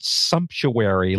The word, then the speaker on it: sumptuary: and there's something called sumptuary law. Am I pronouncing that right? – Yes, you are.